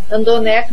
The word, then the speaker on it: randonneur: Porque, como é uma palavra francesa, ó, Randonner, né? Então é R-A-N-D-O-N-N. E-U-R-S, Brasil.